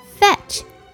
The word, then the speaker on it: fetch: Fetch, F E T C H, fetch 这个字的 T 不发音，fetch